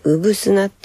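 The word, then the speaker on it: 産土: えっ、ー、と、うぶすなっていう字が、生まれる、生まれるって、えっ、ー、と、産業の産に土と書いて、うぶすなって読むんですね。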